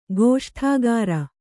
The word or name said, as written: ಗೋಷ್ಠಾಗಾರ